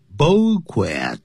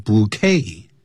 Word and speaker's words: bouquet: And gave her a beautiful bouquet，这个可不要念成 bouquet，因为它是法文变成了英文，念成 bouquet， T 不发音，叫一束，美丽的一束花。